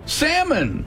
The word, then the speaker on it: salmon: Tied up, Kim. Salmon. Like bears love to eat salmon. What's the silent letter? – L. – L is correct.